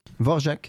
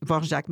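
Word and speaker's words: Dvořák: Vous allez entendre du Bach, aussi du Vorjak, j'ai toujours de difficultés à prononcer, des V-O-R-A-K. – Vorjak. – Oui, Vorjak, merci.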